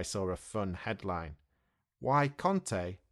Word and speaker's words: Conte: For Conte, the manager, there is an O sound, so we say Conte.